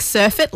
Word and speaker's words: surfeit: Like surfeit, like surfing of the waves? – Well, it's spelled S-U-R-F-E-I-T. Surfeit. – Surfeit? – Pronounced said surfeit.